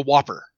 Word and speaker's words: WOPR: Well, it was a NORAD computer called the War Operation Plan Response, pronounced like the cheeseburger, the Whopper.